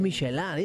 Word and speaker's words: Michelin: Pero es Michelin, ella lo pronuncia bien en francés.